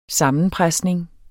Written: sammenpresning

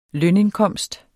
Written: lønindkomst

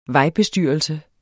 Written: vejbestyrelse

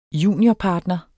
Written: juniorpartner

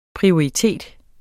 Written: prioritet

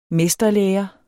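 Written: MESTERLÆRE

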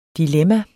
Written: DILEMMAERNE

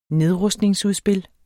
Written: nedrustningsudspil

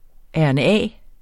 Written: rna

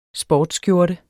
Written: sportsskjorte

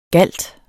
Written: GALTENS

